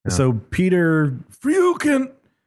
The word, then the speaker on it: Freuchen: You'd want to say Freuchen because his last name is spelled F-R-E-U-C-H-E-N. – But it's more like Freuchen.